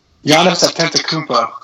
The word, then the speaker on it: Antetokounmpo: But Antetokounmpo, how about that? How about that for a try? – Giannis Antetokounmpo.